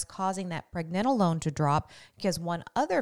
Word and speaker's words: pregnenolone: So pregnenolone or pregnenolone, tomato, tomato, you know, however you want to pronounce that, but I pronounce it pregnenolone, is it's secreted from the adrenal glands, and that can actually drop dramatically.